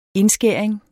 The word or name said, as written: indskæring